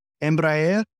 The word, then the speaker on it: Embraer: Embraer? – Yes, that's exactly right.